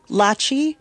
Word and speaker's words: Lachi: Lachi like Versace, girl. – Lachi like Versace. I love that. – I'm Lachi, she, her, black girl, corn, rose, brown skin, big city race.